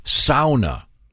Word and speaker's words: sauna: Got this from Randy, who writes the correct pronunciation is sauna. – Yeah, sauna. As every Finlander knows.